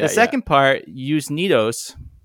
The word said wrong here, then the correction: usneoides: I'm totally butchering it. – Yeah, yeah. – Usneoides. – Usneoides is actually, it means like another plant, like another type of lichen that was called like nodis.